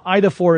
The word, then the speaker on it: Eidophor: Now, IDA 4 is spelled E-I-D-O-P-H-O-R, and technically, if you're being kind of generous, it means image bearer, roughly speaking.